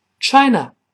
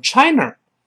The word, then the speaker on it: China: China，有的同学容易把它读成 China，不要读 China。即便在美语中，也不能乱勾舌头。李老师说过，没有字母 r，舌头就不能卷回来。